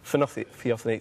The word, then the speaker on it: Theophany: Is that, if I pronounce that right? Theophany, thank you, you do the math.